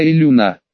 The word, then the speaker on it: Илюна: «Буду звать тебя Илюна». Я постарался выделить ударение на «у», чтобы подчеркнуть различие с небесным телом, но не был уверен, насколько преуспел.